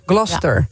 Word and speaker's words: Gloucester: De Engelse plaatsnaam Gloucester spreek je uit als Gloucester. – Gloucester. Ja. Gloucester. Als één vloeiend woord, Gloucester.